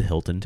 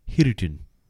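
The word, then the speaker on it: Hiruton (ヒルトン): But like, it's called the Hilton Tokyo. Mm-hmm. However, they don't say Hilton. They say the Japanese equivalent of that, which is a bunch of syllables that sounds like Hiruton.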